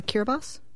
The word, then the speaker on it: Kiribati: Yes, it's most people know it as Kiribati, but it's actually Kiribati.